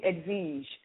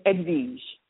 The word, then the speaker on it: Edwige: It's actually Edwige. So pronounce the W like a V. I know, crazy, but that's the French for you. Edwige.